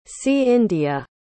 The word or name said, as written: cindia